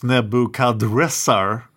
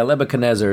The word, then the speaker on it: Nebuchadnezzar: But there's also an argument that he should really be pronounced Nebuchadrezzar. – Yeah, I know. Sometimes it's spelled that way. – Yes, but that doesn't sound good. So we will call him Nebuchadnezzar II.